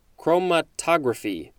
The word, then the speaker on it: chromatography: Next is affinity chromatography. Again, it's always about where is the emphasis, which syllable is emphasized. Chromatography. There we go.